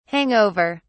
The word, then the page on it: hang over: British English